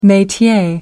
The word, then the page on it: metier: American English